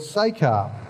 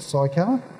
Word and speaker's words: Sychar: Read that he came to a town in Samaria called Sychar. That's the way it's supposed to be pronounced, not Sychar.